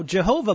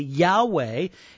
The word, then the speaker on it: Yahweh: We pronounce it as best as we know how, Jehovah, but it's actually Yahweh, and it is the most sacred name for God that the Jews knew.